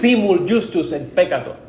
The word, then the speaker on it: simul justus et peccator: I'm trying to pronounce that in the Roman, the Spanish, and my English is coming into that. So it's simul justus et peccator. Okay. It's kind of like Spanish, sounds the same.